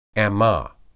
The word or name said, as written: ammah